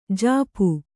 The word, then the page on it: ಝಾಪು: Kannada